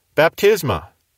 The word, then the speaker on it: baptisma: That is the basic verb, bapto, and that is spelled B-A-P-T-O. Related to bapto is the noun Baptisma. Spelled B-A-P-T-I-S-M-A, baptisma. And then we also have the word baptistes, B-A-P-T-I-S-T-E-S.